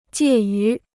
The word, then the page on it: 介于: jiè yú